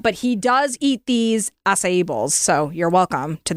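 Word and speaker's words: açaí: Is that how you say it? Acai, acai, it is acai.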